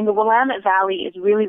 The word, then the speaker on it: Willamette: And for the record, it's a really tricky word if you're not from here. But us locals, we pronounce it Willamette. – Willamette. And one of the ways, yes, and one of the ways that the tour companies have you remember that is they say it's Willamette, damn it.